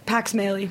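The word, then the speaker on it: Pax Mahle: So how do you pronounce his last name? – Pax Smalley. – Pax Smalley. Yeah.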